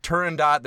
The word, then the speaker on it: Turandot: Turandot, the T's there. Puccini pronounced the T.